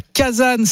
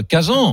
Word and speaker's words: Kazan: Alors je disais Kazan et puis je suis passé à Kazan récemment parce qu'on m'a dit que c'était la meilleure prononciation en français. Mais je pense qu'on peut dire les deux.